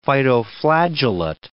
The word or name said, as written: phytoflagellate